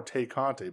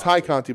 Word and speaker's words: Tay Conti: Which is weird because that makes me want to call her Tay Conti. But yes, it's Ty Conti, but they changed the name to rhyme with Anna Jay.